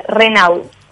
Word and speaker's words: Renau: Se escribe y se pronuncia igual, Renau.